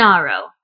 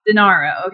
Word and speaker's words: Denaro: So what's his name? Well, just the last name. I don't need his name. – Denaro. – Denaro, okay.